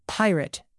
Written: pirate

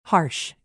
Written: harsh

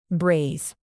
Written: braise

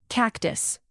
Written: cactus

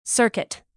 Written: circuit

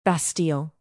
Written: bastille

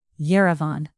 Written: yerevan

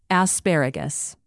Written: asparagus

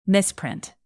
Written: misprint

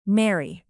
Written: mary

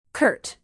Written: curt

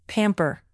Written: pamper

